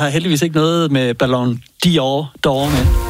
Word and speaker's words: Ballon d'Or: Det var fordi, du sagde åbenbart, jeg undrer mig over, at nyhedsverdenen blev ved med at sige, Ballon Dior er modehuset blevet hovedsponsor. Dior. Nå. Altså, det er ikke mig. – Nå. – Det er fordi, at nå. Folk synes, du... – Ballon d'Or, Ballon d'Or.